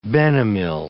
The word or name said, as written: benomyl